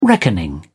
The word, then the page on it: reckoning: British English